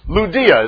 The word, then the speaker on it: Lydia: Lydia is how it's pronounced in the Greek, but Lydia, and she was a worker with purple and with garments.